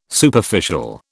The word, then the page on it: Superficial: British English